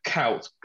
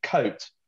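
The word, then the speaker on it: Kuyt: The U-I is actually pronounced O. So when everyone goes Dirk, kout, because I can't say it, or kite, it's actually coat.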